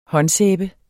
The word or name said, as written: håndsæbe